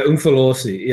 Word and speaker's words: Umfolozi: I started this sentence very strong, and I didn't look at how to pronounce this word. The Umfolozi and St. Lucia wilderness areas? – Yeah, Umfolozi, yeah. – Umfolozi, there we go.